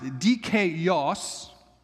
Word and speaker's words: dikaios: It's in its Greek word is pronounced dikaios.